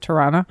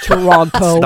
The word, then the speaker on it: Toronto: However, by the way, everybody at Disney, it's pronounced Toronto. Not Toronto.